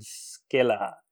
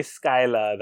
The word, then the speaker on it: Scylla: I'm going to go with Scylla. Or I don't want to say Skyla.